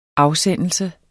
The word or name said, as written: afsendelse